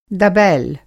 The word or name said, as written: दबैल